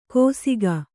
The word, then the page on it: ಕೋಸಿಗ: kōsiga